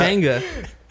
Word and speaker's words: Pechanga: Pechanga. – There's a CH there, my friend.